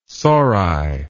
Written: sori

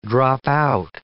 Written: drop out

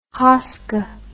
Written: hoska

